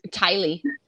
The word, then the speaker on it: Tylee: I'm sorry, I keep calling her Kaylee. Am I saying her name right? – Tylee. – Kylie, there we go.